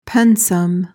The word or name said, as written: pensum